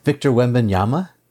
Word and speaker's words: Wembanyama: Do you know Victor Wembin? Let's see, Wembin. What's his name? Victor Wembanyama. Yeah, Victor Wembanyama.